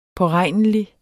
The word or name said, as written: påregnelig